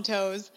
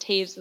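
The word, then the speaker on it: Toews: And I literally called him Toes. And the guy was nice about it, though. Like, he was like, he talked to us anyways, even though I completely mispronounced the name on the back of his jersey.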